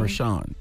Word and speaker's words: Rushion: Yeah, Rashawn. Is that how you say his name? – Rashawn, yeah. – It's Rashawn. – It's spelled R-U-S-H-I-O-N.